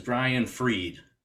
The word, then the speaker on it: Brian Fried: Our guest is Brian Freed.